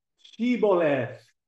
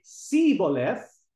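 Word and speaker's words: Shibboleth: They would ask them, pronounce Shiboleth. If they say Siboleth, it means they are from Ephraim, because the Ephraimites, for some reason, were unable to pronounce the H.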